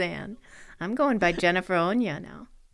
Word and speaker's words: Áine: It's the Irish version of Anne, and it's pronounced Onya.